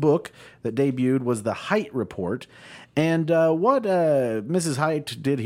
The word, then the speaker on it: Hite: I'm gonna guess it's Shere Hate. Is that how you pronounce it? – Yeah, pronounce it. But Shere Hate, Hite.